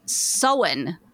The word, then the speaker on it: Samhain: It is spelled like it would be pronounced. Samhain is S-A-M-H-A-I-N, but it is pronounced Sowen.